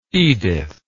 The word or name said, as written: edyth